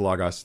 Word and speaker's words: Logos: This is what I love about lo- uh, logos. I know it's pronounced logos. That's how I would pronounce it with Greek, but it's just common pronunciations logos for this stuff.